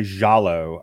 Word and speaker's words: giallo: It's been described as a giallo, if I'm pronouncing that right. Yeah. G-I-A-L-L-O. – That's close enough to the correct pronunciation. We can go with that.